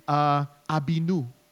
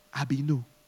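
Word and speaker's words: Avinu: Abinu. Abinu. Father. Abinu. This is has a ba sound, which means that it carries intimacy, like Abba, Father, Abinu.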